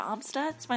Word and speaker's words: Amstutz: You can find me on all social media platforms at Sandra Amstutz. My last name is spelled A-M-S-T-U-T-Z.